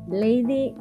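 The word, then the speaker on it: Leydy: Eh, sería lady de em,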